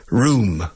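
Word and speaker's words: rheum: Room. Sleep. Mira, dices sleep. Sleep. There's a word I've never seen in my life. It's a medical term. Room. R-H-E-U-M. I suppose it's pronounced room.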